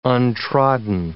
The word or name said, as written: untrodden